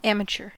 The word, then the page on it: amateur: American English